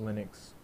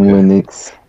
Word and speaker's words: Linux: It's more of a, how do y'all pronounce that? Linux or Linux? – Linux. – Okay. – Linux.